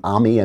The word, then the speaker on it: Ami: There's a guy, he pronounces his name Ami. I may have that wrong. It's Ami Horowitz.